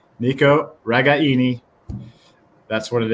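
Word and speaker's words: Ragaini: We learned something today, how to pronounce Nico's last name, which we have all been pronouncing wrong for years now. So apologize to him and his family, but it is Regaini. Emphasis on the I in the third syllable.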